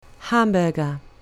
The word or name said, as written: hamburger